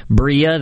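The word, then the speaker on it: Brilla: So within 3 months, we were back with our very first team, Bria. That's the way it's pronounced. And that was also the reason why we came up with that name, was to have a Spanish word. So it's the Spanish word for shine.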